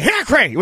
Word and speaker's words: Hickory: You got to say Hickory. We don't pronounce the O.